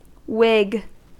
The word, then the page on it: whig: American English